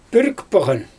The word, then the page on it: pürrikpawGan: Xyzyl